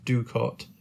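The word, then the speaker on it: doocot: Spelled ducot, pronounced ducot.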